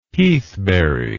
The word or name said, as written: heathberry